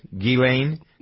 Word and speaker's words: Ghislaine: Ghislaine Max, Ghislaine? – Yes, the S is silent, yes.